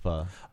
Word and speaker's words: pho: It's spelled pho, but it's pho.